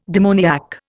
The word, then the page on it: demoniac: Indian